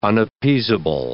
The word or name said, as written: unappeasable